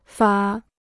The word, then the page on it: 法: fǎ